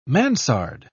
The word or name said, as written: mansard